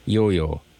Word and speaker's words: IOIO: And I'm going to pronounce that just like a Duncan, Yo-Yo. But it's actually spelled I-O-I-O.